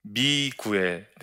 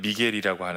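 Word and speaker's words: Miguel: 근데 제가 이 보스턴에 가서 어 정말 크게 하나님께서 깨닫게 해주신 게 한 가지가 있었는데 그때 원기 옆에 미구엘 네, 원래 발음은 미겔이라고 하는데요.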